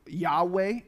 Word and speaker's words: Yahweh: It's Y-H-W-H. Some in the past had thought it was Jehovah, but it turns out that's probably not the closest pronunciation. So Yahweh is what most people receive as what that might have sounded like.